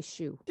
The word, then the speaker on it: choux: The paste that forms that makes eclairs is spelled C-H-O-U-X. How is it pronounced? – I say shoe. – Shoe. – It is shoe.